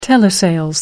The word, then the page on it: telesales: American English